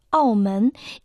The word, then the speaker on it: 澳门: Название города Макао на китайском языке звучит как Аомен и состоит из двух иероглифов. Первый иероглиф Ао произносится в четвертом тоне. В переводе на русский язык это морской борт. Второй иероглиф Мен произносится во втором тоне.